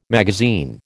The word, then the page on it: magazine: American English